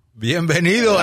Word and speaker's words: Bienvenido: Bienvenido. – Bienvenido.